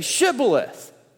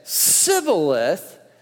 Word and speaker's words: Shibboleth: If he replied, "No," they said, "All right, say Shibboleth." And if he said Sibboleth, because he could not pronounce the word correctly, they seized him and killed him at the fords of Jordan.